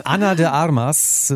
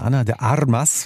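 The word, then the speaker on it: Ana de Armas: Anna de Armas, wie man auf Kuba sagen würde, Anna de Armas. – Wir müssen das heißt aber schön aussprechen. – Schön gerolltes R.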